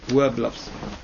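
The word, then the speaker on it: Werblowsky: A Verblovsky spelt with a W. Verblovsky.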